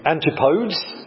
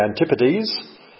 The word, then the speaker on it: Antipodes: Well, some people would mispronounce the Antipodes. It's supposed to be the Antipodes. That's Australia, but more strictly, it's New Zealand.